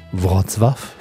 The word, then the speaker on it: Wrocław: Hallo Mirko, richtig Wortswaff? ja, schönen Dank erstmal, dass ich hier sein darf. Hast du schon sehr gut ausgesprochen, würde ich sagen. Also Wrocław sagt man es auf Polnisch und Breslau ist der deutsche Name, aber es geht eigentlich auf den gleichen Wortstamm zurück.